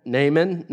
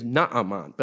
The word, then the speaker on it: Naaman: Naaman, not Amon, right? Naaman rhymes with Haman, but only in the English. one's Haman and the other is Naaman.